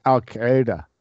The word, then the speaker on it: Al-Qaeda: And for those of you that don't know, in Al-Qaeda, all that means, or Al-Qaeda, you know, I've heard it pronounced so many different ways, but in Arabic, it's Al-Qaeda.